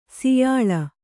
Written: ಸಾಯಾಳು

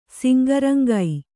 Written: ಸಿಂಗರಂಗೈ

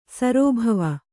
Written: ಸರ್ಬವ

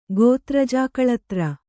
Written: ಗೋತ್ರಜಾಕಳತ್ರ

